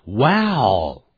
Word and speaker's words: wow: Wow, wow, 哇塞，哦，表示惊讶的这样子说。这怎么一个感叹词，我们就叫做 wow. 念的时候念成 wow, ow.